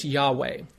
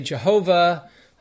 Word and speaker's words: Yahweh: But the four letters that make up the name of God, the Yod, He, Vav, He, we properly say is Yahweh. Now, the Germans say Jehovah. It's a Germanic pronunciation, but we say Yahweh.